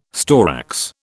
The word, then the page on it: storax: British English